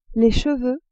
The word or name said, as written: cheveux